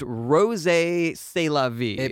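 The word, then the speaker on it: Rrose Sélavy: You had a pseudonym you used, Rose C'est la vie. – Please pronounce the double R. – Rose, like that.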